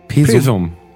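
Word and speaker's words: Pewsum: P-E-W-S-U. Pesum in Ostfriesland? – Pesum.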